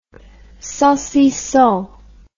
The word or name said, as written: saucisson